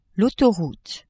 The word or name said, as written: autoroute